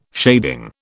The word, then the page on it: shading: British English